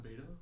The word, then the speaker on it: Beto: Was that Beto? – It's Beto.